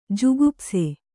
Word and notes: ಜುಗುಪ್ಸೆ: jugupse

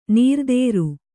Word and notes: ನಿರಾಧಾರ: nirādhāra

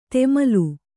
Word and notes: ತೆಮಲು: temalu

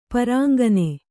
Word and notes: ಪುರಾಂಗನೆ: purāŋgana

